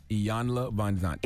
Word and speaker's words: Iyanla Vanzant: How you pronounce her name again? – Iyanla Vanzant. – Iyanla. – Iyanla. – Yes, you did good.